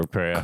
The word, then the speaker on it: Kreia: Is it Kriya or Kreia? In Knights of the Order. – Kreia. Well, I think it's Kreia. Kreia rhymes with Treya.